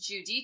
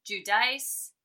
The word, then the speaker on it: Giudice: How the hell do you pronounce Teresa's last name? I am still working on that shit. It's Giudice, Giudice. What is it? – I say Giudice. And the way she said it in her book, she said, if you are in America, it's Giudice. If you're in Italy, it's Giudice.